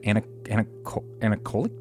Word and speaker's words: anechoic: Anechoic chamber? – Anechoic.